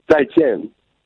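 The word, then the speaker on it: Zai Jian (再见): How do you say it again? – Zai Jian. – Zai Jian. – Almost. Good enough.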